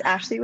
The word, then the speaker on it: Ashlee: So it's Ashley with 2 E's, Carol with 2 R's and 2 L's.